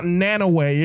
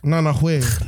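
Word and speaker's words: Nanaue: It's not Nanahue. It's not Nanaway. It's Nanahue.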